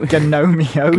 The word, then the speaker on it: Gnomeo: Gnomeo, oh, as in gnomes. – No, yeah, I know. No, I quite like pronouncing the G on gnome, even though it's completely grammatically incorrect.